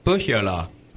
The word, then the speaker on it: Pohjala: Pohjala.